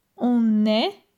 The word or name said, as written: on est